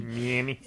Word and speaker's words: Miami: So this came across the Sun Sentinel, which is out of Mama. Is that how you say it? Mama. Oh, Miami. – Miami. – That's how Cuomo says Miami.